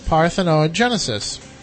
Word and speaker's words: parthenogenesis: Tests revealed their eggs had been developed without being fertilized by sperm, a process called parthenogenesis, the team wrote in the journal Nature. Thank you, Matt. It's always good when the scientist tells you you pronounce the scientific term correctly.